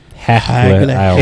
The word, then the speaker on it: Hagle: Hogle. – Hogle? – Hagle. – H-A-G-L-E-H.